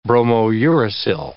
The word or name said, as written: bromouracil